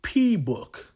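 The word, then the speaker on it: P-Bouk: And that was a Yusef Lateef composition titled P-Book. And that's P hyphenated and then B-O-U-K. He pronounced it as book.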